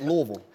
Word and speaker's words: Louisville: At least pronounce it right. it's Louisville.